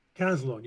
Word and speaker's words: Caslon: Did you mean Caslon? – Caslon, yeah. – Okay. – Yes, I did mean Caslon.